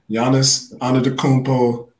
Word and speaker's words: Giannis Antetokounmpo: Giannis Antetokounmpo is an NBA champion.